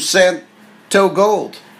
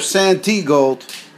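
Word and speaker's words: Santigold: Well, now here's some Santo Gold. I call her Santee Gold. I thought that's the way you're... No, it's spelt T, pronounced O.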